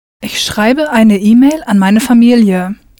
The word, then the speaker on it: schreibe: Richtig, ich schreibe. Pronounce that E ending for the ich person clearly.